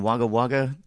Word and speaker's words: Wagga Wagga: Yeah, doing a tour of Canberra and the Wodonga and Wagga Wagga this weekend.